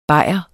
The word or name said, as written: BAJEREN